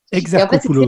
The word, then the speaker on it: Exarchopoulos: Exarchopoulos.